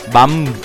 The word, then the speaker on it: BAMF: Bam, bam, bam. También me infla la boca de verdad. B-A-M-F.